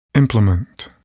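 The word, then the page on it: implement: noun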